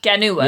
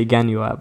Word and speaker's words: Genoa: You could say Genua. – Yeah, I could say Genua, but that would be just not funny. – Okay, well, sorry. – Genua.